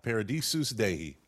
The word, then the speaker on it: Paradisus Dei: Paradisus Dei? – Yeah, Paradise of God, Paradisus Dei.